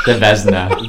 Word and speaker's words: Vezina: Our last award for the NHL season, I'm going to butcher this award name, but Vezina, am I? – The Vezina. – Vezina? – Yeah. – The Vezina? – Yeah. Vezina, yeah.